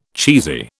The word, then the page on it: cheesy: British English